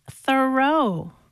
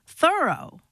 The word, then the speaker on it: Thoreau: All this time, I thought his name was pronounced Thoreau, but no. – How is it pronounced then? – Thoreau.